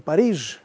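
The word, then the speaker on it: Paris: как французы сегодня говорят Пари понятно, а, а мы говорим Париж.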